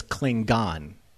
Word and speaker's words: Klingon: I also loved the fact that he says Klingon with a hard G.